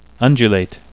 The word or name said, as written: undulate